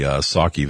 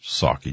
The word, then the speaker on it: Psaki: And of course, Psaki just, remember the P in, her name, last name is silent, just like the P in resident Joe Biden.